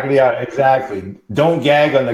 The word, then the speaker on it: Gagliardi: It's Italian, and the Italians would pronounce it Gagliardi, but it's Gagliardi.